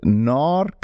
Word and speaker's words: Gnar: The second one is a book called Gnar Country. It's spelled G-N-A-R, Gnar Country, Growing Old, Staying Rad, written by Stephen Kotler.